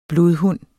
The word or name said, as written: blodhund